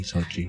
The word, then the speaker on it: Xochitl: Sochi. It's like the Russian city, Sochi.